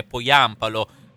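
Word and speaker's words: Pohjanpalo: Nel Venezia è entrato Busio, ma o Busio, che dir si voglia, insomma, noi lo chiamiamo Busio, come ci auguriamo di pronunciare bene il nome Poyampalo, finlandese. Abbiamo fatto delle verifiche, dovrebbe essere questo.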